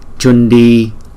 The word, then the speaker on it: Chundi: Chữ C chúng ta đọc là Cha, Chundi Dharani.